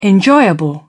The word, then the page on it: enjoyable: British English